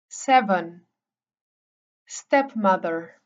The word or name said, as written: stepmother